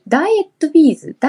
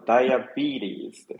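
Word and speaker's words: ダイアビーティーズ: ダイエットビーズダイ、ダイエットビーズ惜しいですね。発音はですね、ダイアビーリーズですね。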